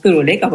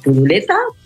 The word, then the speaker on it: Turuleca: Qué maravilla, bien, pues ahora ya sé por qué es turuleca, porque yo decía, ¿turuleta? ¿Turuleca? Yo aún no sabía pronunciarlo hasta que hace poquito con el niño me he vuelto a aprender todas las canciones, pero esta vez ya lo he pronunciado bien.